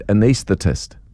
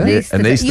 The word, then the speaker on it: anaesthetist: It's actually said anaesthetist. – What? There's a lot of S's there. – Anaesthetist. – You're right, because the S is the anaesthetist. – Yes. And it actually makes it a lot easier to say if you focus on the middle S.